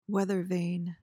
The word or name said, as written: weather vane